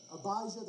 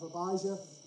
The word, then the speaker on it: Abijah: And then my wife pointed out, you know, it looks a lot like Elijah from the Old Testament. And that makes sense. And so we're Abijah, that's how you say it.